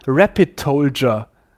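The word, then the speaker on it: Rapid:TLDR: Aber das wird ausgesprochen Rapid Tolger. Also eine Mischung wie Soldier, aber Tolger.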